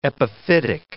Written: epiphytic

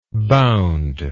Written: boundingly

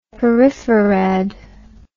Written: peripherad

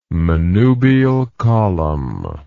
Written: manubial column

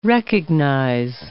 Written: recognize

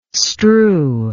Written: strew

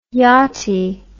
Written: yachtie

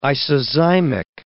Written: isozymic

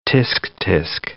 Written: tsk-tsk

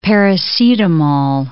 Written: paracetamol

